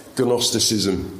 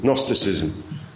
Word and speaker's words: Gnosticism: Gnosticism is called, with a silent G at the top, spelt Gnostic. Gnosticism, Gnosticism. What the Gnostics said or claimed was that they had the knowledge.